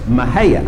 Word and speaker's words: Mexia: And the city is spelled M-E-X-I-A. And the guy driving says, oh, well, never heard of that. There's a city called Mexia. The passenger said, hey, you mispronounced that. It's pronounced Mahia.